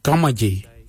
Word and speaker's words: Gamadji: Dederi is spelled D-J-E-R-D-A-R-I. Dederi, brown, Dederi. And the last colour for green, we say gamaji. Gamaji for green. Gamaji is spelled G-A-M-A-D-J-I.